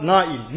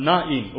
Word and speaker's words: Nain: Wir hören hier, Christus besucht die Stadt Nain. Nicht Nein ausgesprochen, sondern Nain oder im Hebräischen Nain.